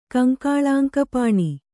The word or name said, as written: ಕಂಕಾಳಾಂಕಪಾಣಿ